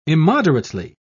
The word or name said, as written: immoderately